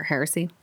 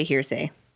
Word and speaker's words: heresy: Or heresy? Goddamn. – Heresy. – I always want to say hearsay. Hearsay but it's not hearsay. – Hearsay now. Heresy. There we go.